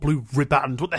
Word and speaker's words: riband: Blue Ribbon? – Ah, I think I've heard this. Apparently that's wrong, isn't it? Is it ribbons? – It's riband. – Oh, riband.